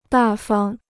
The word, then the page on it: 大方: dà fang